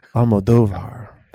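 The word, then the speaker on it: Almodóvar: Almodovar, because it's got that accent on the second O.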